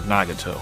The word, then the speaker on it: Nagato: Today in Rank Amateur we will be doing the Tech Tree uh, Tier 7 Japanese battleship Nagato, or Nagato, however you want to pronounce it.